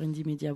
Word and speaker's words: Indymedia: Indie Media, ça s'écrit Indymedia. Voilà, sur Indie Media Bruxelles, il y a plus d'infos.